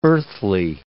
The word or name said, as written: earthly